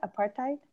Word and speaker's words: apartheid: I'm going to mispronounce this apartheid. Apartheid. – No, you got that right.